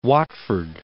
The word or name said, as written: Watford